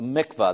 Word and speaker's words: mikveh: A mikveh, that's spelled M-I-K-V-E-H.